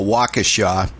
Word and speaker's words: Waukesha: Waukesha is the way we pronounce it around here.